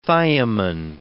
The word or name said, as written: thiamin